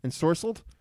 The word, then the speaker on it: Ensorcelled: How would you pronounce that? Ensorcelled? Celsior? – Ensorcelled. – Ensorcelled? – Ensorcelled.